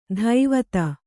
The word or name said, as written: ದೈವತ